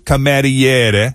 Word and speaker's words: cameriere: Camiere, yes. – So, il camiere. – And now we got you screwed up. – Thank you. – Sorry. – Il cameriere. – Il cameriere.